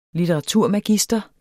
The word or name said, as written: litteraturmagister